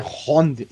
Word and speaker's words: round: Aí, uma errata aqui, eu falei errado, do round. Desculpa. – É round. Infelizmente,